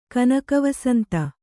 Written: ಕನಕವಸಂತ

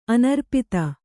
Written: ಅನರ್ಪಿತ

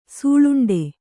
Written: ಸೂಳುಂಡೆ

